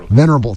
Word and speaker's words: venerable: vulnerable, did I say that right? Vulnerable? Vulnerable? Venerable, thank you.